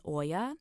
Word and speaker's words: olla: It's a little bit less fancy than the drip irrigation, but if you're intimidated by setting something like that up, it's called an Oya. It's spelled O-Y-A or O-L-L-A.